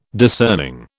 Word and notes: discerning: British English

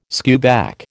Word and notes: skewback: American English

